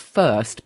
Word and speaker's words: Fhirst: So they're called First, but it's spelled F-H-I-R-S-T.